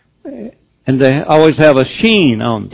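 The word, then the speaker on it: shin: Now, the letter sheen or seen, sometimes it's pronounced as an S like it was there, but usually it's like SH.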